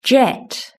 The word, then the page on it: jet: British English